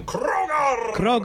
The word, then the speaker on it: Krogar: You gotta roll your R's like this, the creature from Krogar.